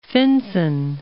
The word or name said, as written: Finsen